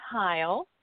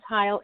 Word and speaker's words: Hile: It's Christiane Heil. And my last name is spelled H-I-L-E. So again, Christiane, C-H-R-I-S-T-I-A-N-E. And last name is Heil, H-I-L-E.